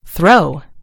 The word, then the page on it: throw: American English